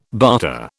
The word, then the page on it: barter: British English